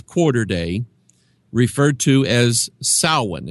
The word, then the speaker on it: Samhain: It took me years to be able to pronounce that right, because it's spelled Samhain. And I thought, well, who is Samhain? I don't know who that is. Well, they pronounce it Samhain for some reason.